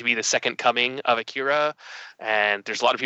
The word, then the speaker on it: Akira: And while he's getting checked out by doctors, it's discovered that his psychic abilities are similar to those of Akira or Akira, to pronounce it more accurately, but less westerny.